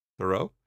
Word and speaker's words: Thoreau: Thoreau? – Thoreau.